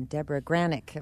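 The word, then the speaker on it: Debra Granik: Winter's Bone was also directed by a woman, Deborah Granick, if I'm saying that right.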